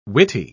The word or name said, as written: witty